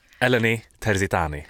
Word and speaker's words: Eleni Terzitani: Snabbt, uttalar vi rätt? Eleni Terzitani. – Alltså helt perfekt. Oh. – Snyggt. Jag har berättat alltid blir lite så italienskt, det sa du till mig. Men det är grekiskt.